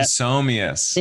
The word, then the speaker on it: Somias: But so, you say the P. You do say the P. I've talked about this with Stella. – Oh, really? – It's Somias. Yeah, Somias. – And she doesn't pronounce her own name right.